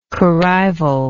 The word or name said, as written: corrival